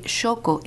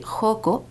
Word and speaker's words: Xoco: También escuchar la pronunciación correcta de Xoco es tan lindo porque en la Ciudad de México escucho mucho decir Joco para decir Xoco.